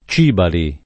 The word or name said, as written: Cibali